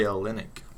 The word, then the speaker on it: Olynyk: Ooh, Kelly Olynyk.